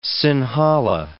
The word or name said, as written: Sinhala